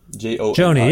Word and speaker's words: Joni: No, do you pronounce it Joni or Johnny? – Joni. – Joni, yeah, like Joni Mitchell?